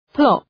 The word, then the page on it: plop: plɒp